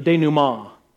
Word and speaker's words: denouement: denouement. Very, very fancy word there at the end. It's French, I think, is what they tell me. And so it should be the denouement.